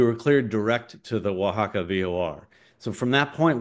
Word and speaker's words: Oaxaca: And it's spelled like Oaxaca, but it's pronounced Oaxaca.